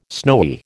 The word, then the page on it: snowy: American English